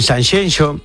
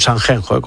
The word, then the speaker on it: Sanxenxo: y te cuento que este fin de semana en Sanxenxo, no Sanxenxo, como dicen. – Qué bien lo pronuncias. – Sanxenxo, ¿eh? – Es como Shakira.